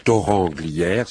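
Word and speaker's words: Thorens-Glières: Alors à Toranglière, ça s'écrit Torrens mais ça se prononce Toranglière, c'est à côté d'Annecy, à une vingtaine de minutes, je crois, 20 minutes, une demi-heure d'Annecy.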